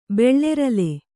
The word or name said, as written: ಬೆಳ್ಳೆರಲೆ